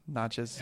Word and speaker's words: Natchez: Natchez. – It was like, dude, it's like matches with an M, but like it's an N.